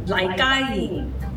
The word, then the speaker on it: Lai Ga Ying: How do I pronounce her name? Is it Lai Ga Ying? – Lai Ga Ying. – Lai Ga Ying. – Lai Ga Ying. Guy like chicken. – Ga. – Okay, Lai Ga Ying. Right, okay, we got that pronunciation.